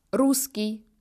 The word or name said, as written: russe